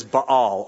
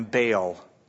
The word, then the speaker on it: Baal: The Babylonians' chief god was known as Baal, or we call him Baal in our pronunciation often.